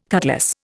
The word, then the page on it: cutlass: Indian